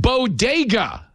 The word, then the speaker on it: bodega: San Antonio and told them they were a bunch of breakfast tacos who were as unique as the Bogadas in the Bronx, except there's only one small problem. It's pronounced bodega.